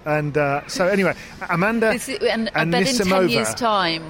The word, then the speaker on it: Amanda Anisimova: This day forward, we will correctly call her Amanda Anisimova.